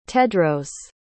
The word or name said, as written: tedros